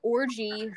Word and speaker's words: Orji: Yvonne Orji, who plays, I think I'm pronouncing her last name wrong. It's O-R-J-I, Orji. I'm sorry. I don't want to say orgy, and it sounds like I'm saying orgy. It's O-R-J-I.